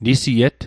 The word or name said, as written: nisiet